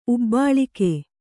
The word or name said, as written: ಉಬ್ಬಾಳಿಕೆ